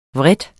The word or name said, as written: VREDESTE